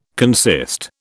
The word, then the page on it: consist: British English